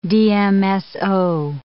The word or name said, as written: DMSO